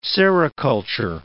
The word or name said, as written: sericulture